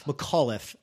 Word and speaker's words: McAuliffe: While McAuliffe, am I saying his name right? – McAuliffe. – He needs to change his name because it's going like it's going Macaulay, and then it just makes this hard turn.